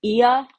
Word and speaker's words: Oia: I think it's like Ia, I'm just gonna spell everything so you can go and look it up yourself. It's spelled OIA.